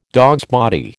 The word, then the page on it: dogsbody: American English